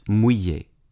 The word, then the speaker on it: mouiller: The modern French word is pronounced mouiller.